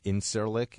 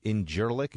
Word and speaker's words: Incirlik: And Turkish President Erdogan, remember G's are kind of pronounced like W's from what I know in Turkish, and the C with a little weird mark under it, like it looks like Incirlik, it's Injirlik, it's like a J.